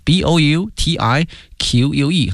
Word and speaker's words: boutique: 精品哈，b o u t i q u e 哈，它的发音是 boutique， boutique hotels， and a nice restaurant，后面出现了与居民区和谐相处，harmony，哎，和谐，harmony，h a r m o n y，harmony，前面加介词 in。